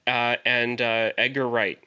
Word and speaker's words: Edgar Wright: And Edgar Wright. Did I get the name right? – Yes.